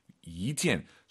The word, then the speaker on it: 一件: 이件. 숫자 1이 2성으로 바뀌어야 돼요. 뒤에가 4성이니까 숫자 1은 2성입니다. 이件 이렇게 가야 됩니다.